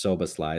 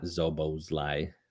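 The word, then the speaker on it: Szoboszlai: So it, it is S's that you pronounce. It's Sobosly. It's not Zobosly.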